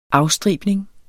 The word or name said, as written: afstribning